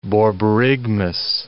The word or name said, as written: borborygmus